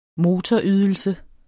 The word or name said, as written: motorydelse